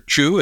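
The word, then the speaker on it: Qiu: And of course, we have found out in the documents that Dr. Qi, or Dr. Chu, as Michael has correctly told me the name is pronounced, that she did work with the Wuhan Institute of Virology.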